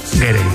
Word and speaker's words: Gere: Estaba pensando yo que si te apellidas Ger, bueno, ¿cómo se dice Ger o Gerde? – Gere. Me ha hecho gracia porque has dicho Richard Gere y luego el hijo era Gere. – Yo creo que es Richard Gere.